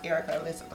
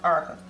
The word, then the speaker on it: Erricka: The, like, my name ain't even pronounced Erica. It's pronounced Erica.